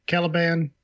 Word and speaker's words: Caliban: Caliban.